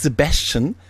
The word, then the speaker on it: Sebastian: Aber das Sebastian wird so ausgesprochen, ja, wie The Beast. Sebastian, The Beast.